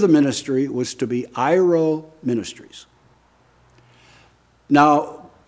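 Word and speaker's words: iro (αἴρω): Okay, English letters would be A-I-R-O, long O, iro.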